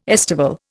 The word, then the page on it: estival: Indian